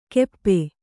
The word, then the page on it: ಗೆಪ್ಪೆ: Kannada